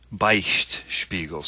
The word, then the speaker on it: Beichtspiegel: Beichtspiegel, so B-E-I-C-H-T. – There's no L? – No L, no Beichtspiegel.